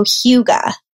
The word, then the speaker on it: Hygge: So it's more like hue, like a color is a hue, and ga, like a baby would say. So Hygge.